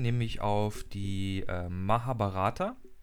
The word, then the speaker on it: Mahabharata: Mahabharata.